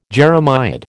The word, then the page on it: jeremiad: American English